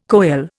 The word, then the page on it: coele: Indian